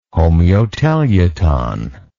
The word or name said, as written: homeoteleuton